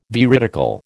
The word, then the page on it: veridical: American English